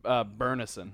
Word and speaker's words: Byrnison: B-Y-R... N-I-S-O-N. – I think it's pronounced Burnison.